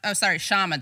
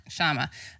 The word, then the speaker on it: Kshama: The K is silent. Kshama. I want to... Is it silent? – No problem. It's not actually silent, but you know what? Even Indians find it hard to say it.